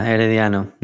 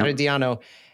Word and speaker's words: Herediano: Yeah, Herediano. Yeah. – Herediano has defeated Toluca on away goals after tying on aggregate.